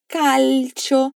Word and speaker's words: calcio: Para dizer que você joga futebol, você pode dizer: presta atenção na pronúncia de calcio. Você, na hora de pronunciar o L, eleva sua língua e depois desce.